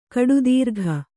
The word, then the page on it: ಕೂಟತರ್ಕ: kūṭatarka